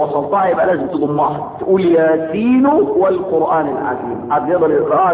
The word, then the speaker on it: ياسين: غلط لانها تنطق ياس ياس تنطق ياس عشان تكتب ياسين الاسم هيبقى ياء ألف سين ياء نون هو ده ياسين.